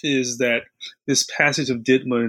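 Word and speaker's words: DIDMCA: So, in 1980, Congress passes a law and it's pronounced sort of DIDMA. That's the shorthand for it.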